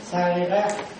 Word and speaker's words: sarira: Rồi, tiếng Bali á, tiếng Bali gọi là Sarira. Sarira tiếng Bali là Sarira. Mà người Trung Hoa họ đọc cái chữ R không được, phát âm họ phát âm không được. Do đó đó, cái chữ R đó nó đọc thành chữ L. Do đó xá, xá lị. Người Trung Hoa nó đọc là xá lị. Do đó đó, xá lị mình đọc ra đây là gì? Xá lợi.